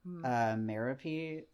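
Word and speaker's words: Merope: I think it's Mariope. So it's Greek. That name Ooh. Is one of the 12 muses. So I assume it's like Calliope, right? And it's M-E-R-O-P-E.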